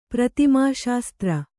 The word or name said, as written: ಪ್ರತಿಮಾಶಾಸ್ತ್ರ